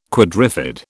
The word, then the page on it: quadrifid: British English